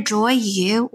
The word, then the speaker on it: Joyu: I'm Joyu, and actually, my name is spelled J-O-Y-U, so it's pronounced either Joyu or Joyu. It's directly translated.